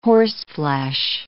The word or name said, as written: horseflesh